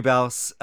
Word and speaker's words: Baus: Uh, do I say Baus or is that how you pronounce it? Correct. Rhymes with house. – No, yeah, there we go. Uh, Gregory Baus,